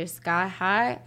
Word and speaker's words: Skai Hye: So you can find me on all streaming platforms and social media platforms under Skai Hye.